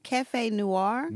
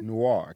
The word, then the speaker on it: Cafe Noir: How do you pronounce it? – Cafe Noir. – Noir. Cafe Noir.